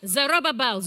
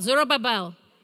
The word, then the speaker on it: Zerubbabel: How do you pronounce this? Zerubbabel, Zerubbabel. Okay, in Russian it's Zaravavel. So I need to relearn how to say all of those names. And in Jewish it's even different.